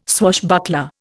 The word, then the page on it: swashbuckler: Indian